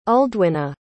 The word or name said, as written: uldwyna